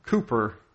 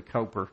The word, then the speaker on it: Cowper: And William Cooper is what they would say. We would say Cowper because it's C-O-W-P-E-R. The British pronounce things funny, don't they, Bill?